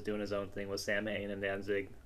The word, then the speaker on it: Samhain: Yeah, Samhain, however you fucking pronounce that one. – I think you said it right the first time.